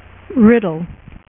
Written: Riddler

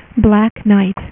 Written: Black Knight

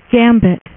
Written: gambit

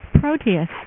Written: proteus

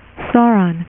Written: Sauron

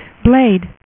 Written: Blade

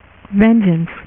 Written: Vengeance

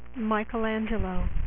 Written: michaelangelo

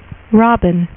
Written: Robin

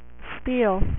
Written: steel

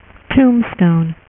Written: Tombstone

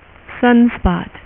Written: sunspot

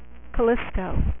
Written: callisto

